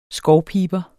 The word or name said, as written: skovpiber